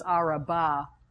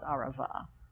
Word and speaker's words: Arava: Okay, so you may see it in your text as Araba with a B, but it's pronounced Arava, just for a little confusion here.